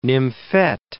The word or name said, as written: nymphet